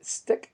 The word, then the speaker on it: Styck: I also have a mobile app called Stick app, but it's spelled STYCK.